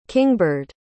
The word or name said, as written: kingbird